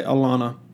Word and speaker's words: Atlanta: And so, or should I say Alana, pronounced in the appropriate dialect, excuse me, Alana.